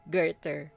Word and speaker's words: Goethe: So, ang una nating ano is si Johann Wolfgang von Goethe. Oh, diba? – Wow. – Pero yung spelling niya, iba. As in, sobrang layo dun sa pagkakabigkas. Yung Goethe, it's G-O-E-T-H-E.